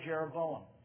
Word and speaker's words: Jeroboam: And initially, the northern kingdom was ruled by a man named Jeroboam. Rhymes with Rehoboam. But Jeroboam was a bad guy.